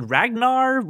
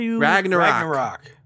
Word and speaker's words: Ragnarok: November 3rd, is it Ragnar? – Ragnarok.